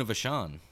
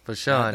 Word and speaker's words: Vachon: Is it pronounced Vachon? – Luna Vachon. – Vachon.